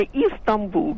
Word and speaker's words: Истамбул: Вот она окончает свой репортаж и говорит «Стамбул». Вот скажите мне, пожалуйста, в каком языке произносится название? Если уж мы говорим изначально, то это «Истамбул», да? Первая буква «И» стоит в английском и в любых других.